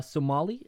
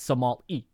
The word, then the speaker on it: Somali: Somali? Is that Somali? Yeah. Sorry. It's highlighted in orange, so I keep thinking it's Somal. But no, it's Somali.